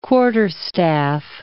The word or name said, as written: quarterstaff